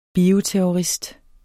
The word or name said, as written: bioterrorist